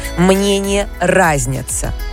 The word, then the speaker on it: разнятся: И это очень часто неправильная формулировка, потому что есть единственное верное ударение в этой фразе. Мнения разнятся. – И тишки на жизнь. – Представляете? Мнения не разнятся, а мнения разнятся.